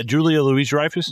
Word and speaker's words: Julia Louis-Dreyfus: Julia Louis-Dreyfus, am I saying her name correctly? Am I remembering that? The from Seinfeld, and she's also in Veep. – Yeah, Julia Louis-Dreyfus.